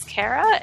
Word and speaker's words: Kara: You can tweet me at Xandara, X-A-N-D-A-R-A. And my name is Kara, and it's pronounced funny compared to Xandara.